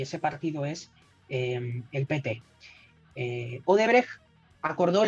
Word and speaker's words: PT: Eh, hemos hablado de Lula, ahora si te parece hablamos de la estructura de partido, hablamos de, del PT, nadie confunda la sílaba tónica para los argentinos, em, porque evidentemente sabemos que en política no existen peces eh, solos, ¿no?